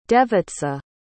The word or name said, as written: devica